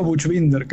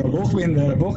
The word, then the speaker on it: Buchbinder: Yo digo Buchbinder, que es como la pronunciación medio castellanizada, sí. Pero Buchbinder, esa es la pronunciación alemana, está increíble.